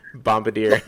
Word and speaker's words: Bombardier: I was going to pronounce. Bombardier. – I say it right. – Bombardier. – Bombardier. – All right. Yes, you pronounced it right.